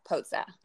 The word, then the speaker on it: Pozza: It's like pizza with an O, pozza.